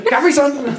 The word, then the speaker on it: Capri Sun: Like Capricorn. It's like Capricorn. Capri Sun.